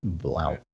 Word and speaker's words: Blount: Blount. – No, he said it right. That's how he's been playing this year. Blount.